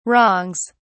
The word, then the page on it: wrongs: British English